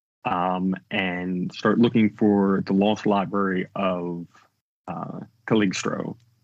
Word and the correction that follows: Cagliostro: which I'm sure I'm saying wrong, and I even tried to spell it phonetically, folks. – Cagliostro. – Cagliostro, thank you.